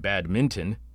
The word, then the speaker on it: badminton: Everyone drops the N, so it sounds like badminton, which sounds like some kind of derogatory term to the Lower Peninsula of Michigan. And anyone who pronounces the N as in badminton will be outed as a Canadian.